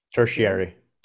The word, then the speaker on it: tertiary: 57, so that's going to be, everybody's going to be in the third selection of the Terry, how do you pronounce that? – Tertiary.